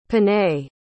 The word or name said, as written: panay